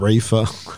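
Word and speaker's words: Reifer: I'm pretty sure the guy's name is Raymond Reefer. Sorry. That probably makes it a bit more offensive, but I'm pretty sure it is how you pronounce it.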